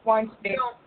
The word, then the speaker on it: Weinstein: after making those horrific jokes about Harvey Weinstein. And I don't know if I'm saying that right. – Weinstein.